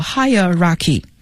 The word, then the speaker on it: hierarchy: 那提到级别，有一个单词叫 hierarchy，等级 H-I-E-R-A-R-C-H-Y， hierarchy 啊，这里边的读音呢，你可以记 higher 就是更高的，所以它是等级的意思。